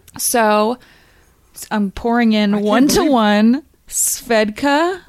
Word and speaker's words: Svedka: I'm excited because I'm sitting here right now with a bottle of Svedka vodka. That's how you say it.